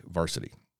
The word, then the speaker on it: VRSTY: It's spelled VRSTY, Varsity.